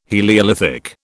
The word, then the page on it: heliolithic: British English